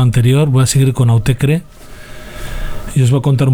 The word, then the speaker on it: Autechre: Es un dúo Autecre, lo pronuncian ellos, aunque es una palabra inventada.